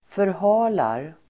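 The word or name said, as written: förhalar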